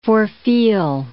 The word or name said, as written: forefeel